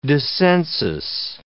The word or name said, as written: dissensus